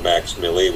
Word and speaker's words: MVXMILLI: Max Fink, who goes by the name of Max Millie, which is spelled MVXMILLI.